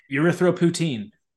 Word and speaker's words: erythropoietin: Hopefully it's because not many people are using it, but unfortunately EPO, which stands for erythropoietin or something, I'm not exactly sure how it's pronounced, but erythropoietin. – Yes, that.